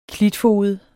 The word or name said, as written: klitfoged